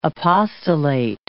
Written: apostolate

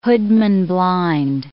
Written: hoodman-blind

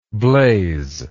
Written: Blaise

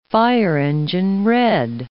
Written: fire-engine red

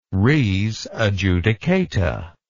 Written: res adjudicata

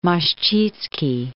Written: Moscicki

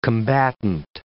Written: combatant

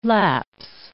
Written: lapse

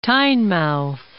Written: Tynemouth

